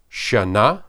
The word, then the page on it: shanah: hebrew